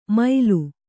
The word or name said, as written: ಮೈಲು